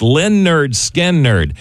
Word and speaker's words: Lynyrd Skynyrd: Eagle, 98.1, the rock station, celebrating the album release on this date, August the 13th in 1973, pronounced Lynyrd Skynyrd.